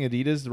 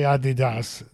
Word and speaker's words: Adidas: Have I been pronouncing Adidas wrong this entire time? – Well, the Europeans say Adidas. Yeah. The Americans say Adidas.